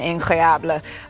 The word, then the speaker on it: incroyable: Yeah, it really has been incroyable. He had a stretch. – That's actually how you say it.